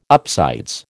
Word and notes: upsides: American English

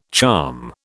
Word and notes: charm: British English

